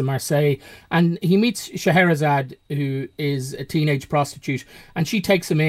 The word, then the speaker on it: Scheherazade: Yeah, this is Scheherazade, is how it's pronounced. – Yes, Scheherazade.